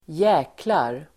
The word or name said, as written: djäklar